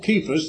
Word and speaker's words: Cephas: After that, he was seen of Cephas, that's the pronunciation, and that's the pet name that Christ tagged Peter with, Petros, meaning Rocky.